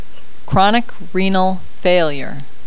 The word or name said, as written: chronic renal failure